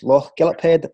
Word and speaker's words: Lochgilphead: Uh, previous schools before that, now how do you pronounce this? It was Lochgilphead and Moffat well, Academy. – Yep.